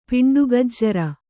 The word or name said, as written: ಪಿಂಡುಗಜ್ಜರ